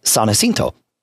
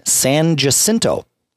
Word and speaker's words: San Jacinto: It is spelled S-A-N space J-A-C-I-N-T-O. San Jacinto is the name of that particular person, saint. Everybody in Austin calls that street San Jacinto.